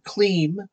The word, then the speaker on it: kleem: So let's work with this very powerful Sanskrit sound, kleem, the sound of attraction. K-L-E-E-M is how you would spell it phonetically in English.